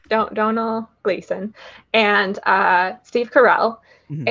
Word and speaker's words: Domhnall: So now it's like clicked in with me as Donal, not Domhnall.